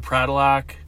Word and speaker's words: Pradilak: It's Andrew Pradilak. You know, everybody pronounces my last name wrong because of the way it's spelled. – Yeah. – People say Prituliak, Pritriak, whatever. They brutalize it.